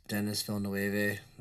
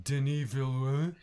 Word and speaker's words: Denis Villeneuve: The director of Sicario was Denis Villeneuve. Benicio Del Toro was in it, along with Victor Garber, FBI agent. – It's pronounced Denis Villeneuve.